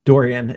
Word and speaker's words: Dorean: Dorean is how I pronounce it. Of course, like you know, if you were going to pronounce it uh, using you know Erasmian Greek pronunciation, be like Dorean, I think. Yeah. But, uh, but yeah, no, I, I find it easiest just to say Dorean.